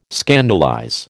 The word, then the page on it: scandalize: American English